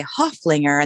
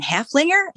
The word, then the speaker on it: Haflinger: And I referred to her as a Huflinger, and they're like, "You mean Haflinger?" And I'm like, "No, I am using the correct pronunciation." So I think it's just us Americans that have screwed that up.